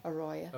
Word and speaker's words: arroyo: Pronounce it for me. – Arroyo.